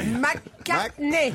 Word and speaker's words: McCartney: Celui-là, McCartney. – McCartney. – Articule. – Ah, excusez-moi, j'ai l'accent de Perpignan. – Il faut absolument que tu prononces aussi les consonnes.